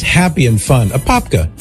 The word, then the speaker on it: Apopka: And Jeanette in Apopka. I hope I'm saying it right, Mary. Does that sound right? Apopka, Florida? – That sounds perfect to me. – Right on. Apopka, Florida.